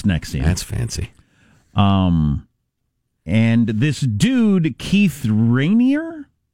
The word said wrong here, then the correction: Raniere: Raniere, uh, also known as the vanguard if you're in NXIVM Wow.